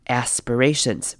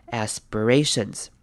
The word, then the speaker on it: aspirations: 来看今天第一个重点的是 aspirations 这个字，这个字要特别注意的是前面短母音 a aspirations aspirations。好，接下来是 sp 的部分呢，我们会把 sp 念成 sp sp，像是 b 的声音，所以会念 aspirations aspirations。接下来要注意的是这边的 IR，虽然它是 I，但是这边我们要念成 ER 的声音，念成 ER， aspirations， aspirations，所以这个字可以自己多练习几遍，把那个声音熟悉一下， aspirations。